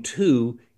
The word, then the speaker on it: two: The W in two is not associated with any pronunciation, but that's not irregular.